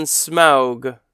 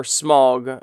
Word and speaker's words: Smaug: Let's go ahead and click on Smaug, or Smaug. I've heard it pronounced both ways, Smaug.